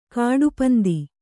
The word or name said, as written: ಕಡುಪಂಥ